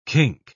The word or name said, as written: kink